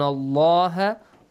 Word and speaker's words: Allah: Wa'lamu anna allaha ghaniyun hamid. Anna allaha. Nasal sound. Allah, lam is pronounced with a heavy sound.